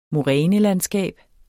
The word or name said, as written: morænelandskab